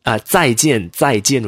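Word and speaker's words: Zai jian (再见): So when you hear people pronouncing z, something z, it's actually supposed to be z. And like zai. Zai jian would be Z-A-I, zai, jian.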